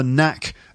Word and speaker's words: knack: because he'd lost the knack of being able to read for pleasure. So the expression here is the knack of doing something. Knack is spelt K-N-A-C-K. It's a silent K.